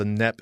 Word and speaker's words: Knepp: I want to begin with a very simple question about your daily opportunity to be out and about on the Knepp Estate, silent K, K-N-E-P-P, this vast tract of land that was inherited by your husband.